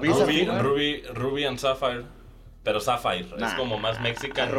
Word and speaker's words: Sapphire: Que es Sapphire. – Sapphire. – La pronunciación.